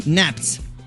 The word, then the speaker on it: Naps: Gwen dice anche confermo la pronuncia di Naps con okay.